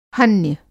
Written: हन्य

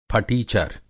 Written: फटीचर

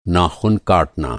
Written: नाख़ुन

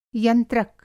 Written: यंत्रक

